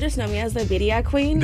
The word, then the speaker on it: birria: You got to roll your R. So if you can, then you got it. – Okay. – It's birria. Yes.